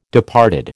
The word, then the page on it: departed: American English